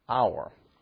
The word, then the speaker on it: hour: But like the English word hour, we don't really pronounce the ch in it. We drop it. And if you're from certain areas of the country, you may not even know that it even had an H to begin with.